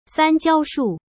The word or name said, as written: sanjiaoshu